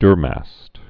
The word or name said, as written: durmast oak